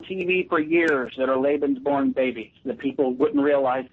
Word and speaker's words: Lebensborn: I don't know if it's pronounced Lebensborn or Lebensborn. – Lebensborn. – Program. – Lebensborn. Yeah. – Leben, that's right, Lebensborn program.